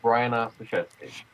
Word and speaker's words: Brian Ostaszewski: My name is Brian Ostaszewski.